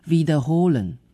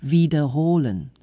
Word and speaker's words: wiederholen: 好，因为重复咯， repeat 哦，英文是这样，德文是 wiederholen，重音在后面咯，这个动词有点长， wiederholen， wiederholen， OK，它是一个 V，也就是英文字母 W 开头的，那德语的发音希望你咬住你的嘴唇， wiederholen， wiederholen，那它就是重复的意思。